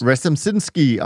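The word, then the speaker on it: Rzepczynski: But also Mark, whose surname I can never pronounce. – Resimczynski, our there you go.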